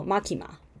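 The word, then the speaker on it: マキマ: 好，那我刚刚讲到 Makima，好，他的名字呢就是曾纪珍啊，哈，他的名字是片假名，好，片假名的发音是 Makima，没有汉字的。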